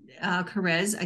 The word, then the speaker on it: Kares: So Alan Carrez, again, I'm going to spell that Alan, A-L-L-E-N, Carrez, K-A-R-E-S. It's pronounced like caress, and I love that.